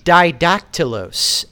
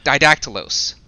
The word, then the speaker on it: Didactylos: It's Didactylos, as in... – Didactylos. – Two dactyls, as in two fingers, because he's flipping the world off.